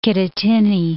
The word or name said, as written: Kittatinny Mountain